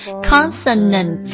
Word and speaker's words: consonants: 那这个 t 加了 s 之后，这个 t 跟死放在一起啊，就要读成我们拼音当中的平舌呲了，但是不能够呲出来，它是一股气流而已啊。 consonants。